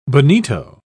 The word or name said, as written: bonito